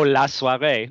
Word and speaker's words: La Soirée: Next up on the agenda, Michael got to see, I'm going to butcher the French, La Soirée. – Oh, La Soirée. No, that was good.